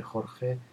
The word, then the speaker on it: Jorge: Eh, siempre se lían mucho pronunciar mi nombre Jorge, les cuesta mucho, entonces a veces me dicen George, George, Jorge, Gorge, Gor, me han dicho de todo.